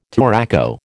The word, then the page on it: touraco: American English